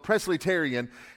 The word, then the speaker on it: Presleyterian: It's called Presleyterian, not, not almost like Presbyterian, but it's called Presleyterian.